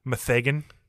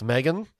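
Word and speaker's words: M3GAN: Do you still pronounce it Megan, or do you try to somehow pronounce that three? Is the No, E? It's Megan. – It's not Methagan? – No.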